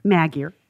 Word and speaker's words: Magier: Magyar. – And I can't even say that. Leanne Magyar, it's spelled like it should be pronounced Magier, M-A-G-I-E-R.